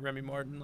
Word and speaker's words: Remy Martin: The beginning of the season were Luka Garza and Remy Martin out of yes, I said his name right, Remy Martin, like the alcohol, out of ASU.